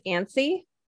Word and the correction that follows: anise: A-N-I-S-E. Thank you, Paul. I don't know how it's actually pronounced in real life. I just know anise. How to find it on the shelf. – Okay. Anise. Star anise.